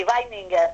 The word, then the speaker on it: Weininger: de Otto Weininger. No sé si lo he pronunciado bien. – Sí, Weininger.